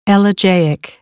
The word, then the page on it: elegiac: American English; ə - ɪ